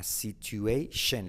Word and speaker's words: situation: Bridge, a situation, letra clave SH, shen, no es tion, sino shen, situation, to bridge a situation, salvar una situación.